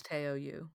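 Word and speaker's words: Teo Yoo: It comes from the fact that Greta Lee, and I'm going to say his name wrong, I'm sorry, is it Teo or Teo Yu? – I believe it's Teo Yu.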